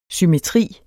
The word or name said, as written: symmetri